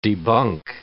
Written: debunk

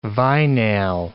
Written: vinal

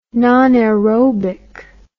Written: nonaerobic

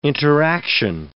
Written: interaction

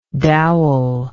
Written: doweling

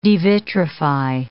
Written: devitrify

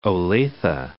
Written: Olathe

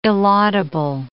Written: illaudable

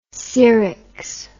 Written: ceryx

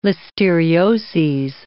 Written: listerioses

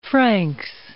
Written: Franks

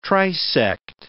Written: trisect

